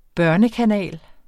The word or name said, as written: børnekanal